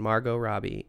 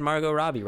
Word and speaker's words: Margot Robbie: And Margot Robbie in Bombshell. I got her name right. – You did. You said Margot Robbie right this time.